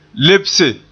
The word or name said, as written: lebse